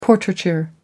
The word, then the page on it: portraiture: American English